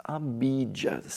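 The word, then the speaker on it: abhijjhā: Now, the Pali word abhijjha, not to be confounded with avijja. Avijja is your ignorance. Abhijja, spelled as A-B-H-I-J-J-H long A.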